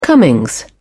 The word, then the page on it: comings: British English